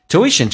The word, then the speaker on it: tuition: Tuition 这个单词英式发音 tuition，美式发音可以把 u 直接发成 a 这个发音，因为重音在后面，前面很多元音都可以弱化成 a 叫 t，那么 a 又跟后面的元音形成个连音，元音跟元音之间内部的连音不是加 w 就是加 y，那这个加一个 w 叫 tuition， tuition.